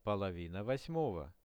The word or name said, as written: polovina 8-ogo